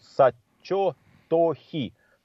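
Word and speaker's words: сачо-то-хи (薩長土肥): Так называемые по-японски их часто произносят сачо-то-хи.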